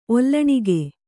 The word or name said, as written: ಒಲ್ಲಣಿಗೆ